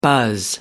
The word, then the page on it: buzz: American English